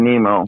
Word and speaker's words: Nimmo: Brandon Nimmo.